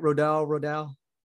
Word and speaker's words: Rodal: Rodal? – Rodal, yeah.